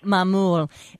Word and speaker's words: Maamoul: Mamoul. – And they're so... – M-A-A-M-O-U-L, yes.